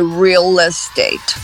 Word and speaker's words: real estate: Não é really, não, é só real, mas ela fica com duas sílabas aí, porque ela emenda com o Ezinho do estate e fica real estate.